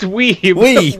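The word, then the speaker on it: Wiebe: Erica, is it Weeby? How do you say her name? – It's Weeby. We might call her Weeby, though.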